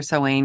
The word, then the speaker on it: Samhain: Well, and I think the whole idea too is like, I guess they're distracted by the preparations for Samhain because that's like the big festival that would matter.